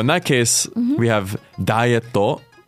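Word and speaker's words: ダイエット: So just to go over pronunciation, we have dietto. – Dietto. – So a small tsu between the e and the to.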